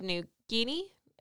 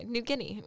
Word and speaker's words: New Guinea: A subspecies can be found on the nearby island of New Guinea. I know I said that wrong. – Guinea. – Yeah, oh, I did. Okay. Yeah. Look at that. – It's a guinea pig. – Yeah. Okay. New Guinea.